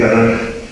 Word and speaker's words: Tanaj: La Kaf final se pronuncia como Jota, entonces uniendo las consonantes se lee Tanaj.